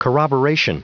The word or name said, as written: corroboration